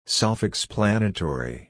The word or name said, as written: self-explanatory